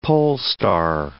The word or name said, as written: polestar